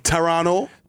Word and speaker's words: Toronto: Toronto. – Don't pronounce that last T. – Don't pronounce that T, dog.